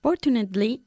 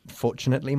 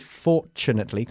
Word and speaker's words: fortunately: Fortunately, my wallet floated. Por cierto, se dice fortunately con un sonido de ch y no fortunately, ¿ok? Fortunately.